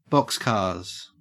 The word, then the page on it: boxcars: Australian English